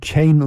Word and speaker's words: Keane: Von der Frau Monika wird dann nicht Keen ausgesprochen. Nein, wird Kane ausgesprochen.